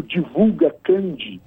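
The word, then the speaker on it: DivulgaCand: Quem entrar no sistema de divulgação do TSE, que eu sugiro que entre no Divulga Candi, esse D é mudo, né?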